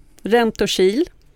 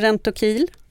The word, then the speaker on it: Rentokil: Sen har vi Andreas Tapper från företaget som kan uttalas på två olika sätt. Rentokil eller Rentokil.